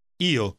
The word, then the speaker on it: Io: Io.